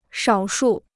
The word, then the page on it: 少数: shǎo shù